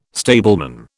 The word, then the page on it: stableman: British English